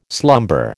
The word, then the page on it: slumber: American English